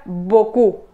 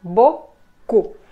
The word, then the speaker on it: beaucoup: Y también tenemos la palabra beaucoup, beaucoup. Si se fijan, beaucoup se escribe muy grande, pero solo se pronuncia bo-cu, porque también tenemos la combinación de letras E-A-U.